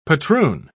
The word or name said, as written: patroon